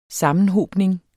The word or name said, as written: sammenhobning